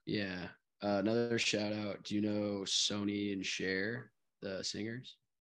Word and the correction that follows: Sonny: Sonny and Cher. Sorry, not Sony. Mm-hmm. It's just spelled like Sony.